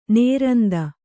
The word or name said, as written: ನಿರಂತ